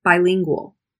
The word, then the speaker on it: bilingual: Bilingual. When you see D-I-T-R-I or B-I as prefixes, they will be pronounced most often die, try, buy, not B, tree, D. So bilingual.